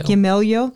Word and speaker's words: Gamaliel: His name's Gamaliel. Is that how you say it, right? – Gamaliel.